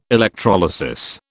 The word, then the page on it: electrolysis: British English